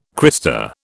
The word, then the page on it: crista: British English